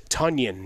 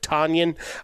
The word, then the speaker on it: Tonyan: Robert Tonyan, not to be confused with Tanyan. Uh, it rhymes with Funyon, uh, as we've been told.